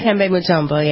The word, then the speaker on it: Dikembe Mutombo: Dikembe Mutombo. What is this guy? I don't get this guy's name, but I never, right? – Dikembe Mutombo, yeah.